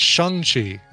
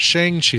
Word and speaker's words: Shang-Chi: By the way, I'm hearing the commercial pronounces it Shang-Chi and not Shang-Chi, like we've been, like I've been saying my entire life, Shang-Chi.